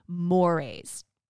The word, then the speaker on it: mores: Now, the first category of presuppositions that Richards and O'Brien highlight in the book are mores. Now, that's spelled M-O-R-E-S, but it's pronounced mores.